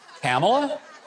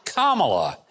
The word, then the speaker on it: Kamala: Kamala? I thought it was Kamala.